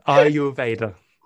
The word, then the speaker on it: Ayurveda: Ayurveda. Oh, I've said it wrong again. – Ayurveda. I just think like Darth Vader.